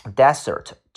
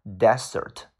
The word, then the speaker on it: desert: 下个单词读 desert，记住这个 s 发 s，desert 叫沙漠。 Desert 叫沙漠，如果你读成 desert，它的意思叫做把什么什么东西去抛弃、去扔弃。比如说一个被扔弃、被抛弃的一个小孩儿，叫做 a deserted baby。